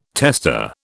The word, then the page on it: testa: British English